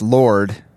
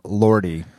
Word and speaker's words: Lorde: Apparently, I pronounced the artist Lord. It's spelled L-O-R-D-E. – Right. – I pronounced her name Lordy.